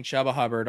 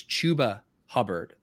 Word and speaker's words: Chuba Hubbard: I had been saying Chuba Hubbard all off season, and then I was scolded, and I learned that the correct pronunciation is Chuba Hubbard.